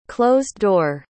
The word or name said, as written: closed-door